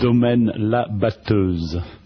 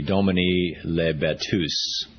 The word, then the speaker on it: Domaine La Batteuse: Domaine La Batteuse. – Uh-huh. So you can see why I can't say it. The lousy English edition would be Domaine La Batteuse. That's the horrible American pronunciation.